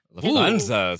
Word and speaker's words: Lufthansa: Okay, I need to know if I'm saying this right. Lufthansa? – Lufthansa. Ooh, that was, yeah, close enough.